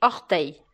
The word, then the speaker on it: orteil: E esse som se escreve essa palavra se escreve O-R-T-E-I-L. Então você percebe que o som EI do final da palavra se escreve E-I-L.